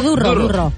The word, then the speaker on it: Durro: Ho pronuncio bé? Durro? – No, Durro. – Durro, perquè és aranès.